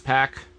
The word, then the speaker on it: Pac: Found out it's pronounced Pac. – It is apparently pronounced Pac. – Like a rumble pack. Like a wolf pack.